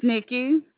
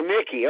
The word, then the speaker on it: Nikki: It's Nikki. – Nadine? – It's Nikki. – Can you spell it, please? – Yes, sir. N-I. – Okay. – K-K-I. – K-K-I. Oh, Nikki. Okay.